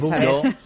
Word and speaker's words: Bordeaux: Bordeaux, bien.